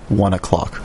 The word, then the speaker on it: One OK Rock: It's pronounced in Japan 1 o'clock.